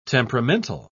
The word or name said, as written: temperamental